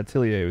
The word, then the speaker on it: Atelier: That's where I got my start with the Atelier games, or Atelier, however you want to pronounce yeah, it. Yeah, Atelier was how I always heard it.